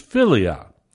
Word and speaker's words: philia: The first is philia. If you had to transliterate that, it would be P-H-I-L-I-A, philia.